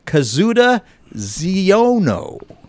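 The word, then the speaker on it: Kazuda Xiono: The new show revolves around a character named Kazuda Ziono.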